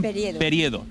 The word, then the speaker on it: Periedo: Es alcaldesa de Casar de Periedo. ¿Lo he dicho bien? – Periedo.